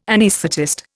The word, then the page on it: anaesthetist: Indian